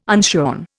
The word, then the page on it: unshorn: Indian